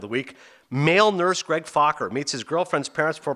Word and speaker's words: Fokker: How do you pronounce it? Just like it's spelled. Fokker.